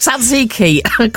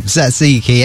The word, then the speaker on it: tzatziki: There's a lot of T's and Z's in that, and I'm never quite sure. Just tzatziki. Cough through it. It'll be all right. – Tzatziki.